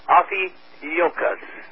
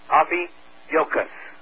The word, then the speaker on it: Ophiuchus: Ophiuchus. Ophiuchus. That's the 13th sign. You'll pronounce it O-F-F-E-E-Y-O-O Cus, C-U-S-S. Ophiuchus.